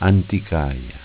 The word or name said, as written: Anticaia